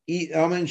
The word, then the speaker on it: amen: If you don't say the last letter, you don't say the nun at the end of the bracha, amen has to be recited as two syllables. Amen. It's two syllables. It's a two syllable word.